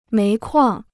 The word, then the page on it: 煤矿: méi kuàng